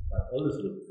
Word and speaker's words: Bad Oldesloe: Bad Oldesloe, habe ich das richtig ausgesprochen? Bad Oldesloe, genau.